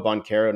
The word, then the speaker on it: Banchero: You said Paolo Banchero, not Banchero. You are part of Draft Twitter now. That's the right way to say it.